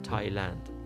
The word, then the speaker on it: Thailand: دقیقا شبیه تلفظ کشور تایلند اگه اسپل کنم در زبان انگلیسی T H A I L A N D یک بار دیگه من این رو تلفظ میکنم منظرت میخوام سپل میکنم T H A I L A N D خب قاعدتا اگه T H باشه باید بگن تایلند ولی نمیگن میگن تایلند